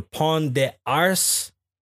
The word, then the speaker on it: Pont des Arts: It was the most famous bridge in Paris, the Pont des Arts. You don't pronounce the T's.